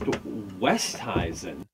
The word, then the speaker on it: Oosthuizen: Oosthuizen said once in an interview that it's supposed to be pronounced Westhuizen.